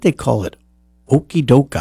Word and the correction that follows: Ocracoke: Island in North Carolina. If I'm pronouncing it. Ocracoke. – What's it called again? – Ocracoke. – Ocracoke, yeah.